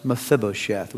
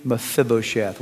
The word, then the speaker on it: Mephibosheth: His name was Mephibosheth. Would you say his name with me? Mephibosheth.